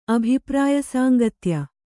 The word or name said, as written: ಅಭಿಪ್ರಾಯಸಾಂಗತ್ಯ